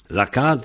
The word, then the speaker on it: lakaat: Lacat s'écrit L-A-K-A et A-T. A-T ou A-D, selon les orthographes des uns et des autres. Mais ici, en finale, ça se prononce T, donc lacat. L-A-K-A-A-T. Alors, l'accent est sur l'avant-dernière syllabe, c'est-à-dire avant la finale at, donc lacat. Ce qui fait qu'en fait, l'accent est sur la finale, lacat.